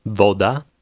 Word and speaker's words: Woda: The letter W is almost always pronounced like the V in the English word very. Read number 1. – Woda.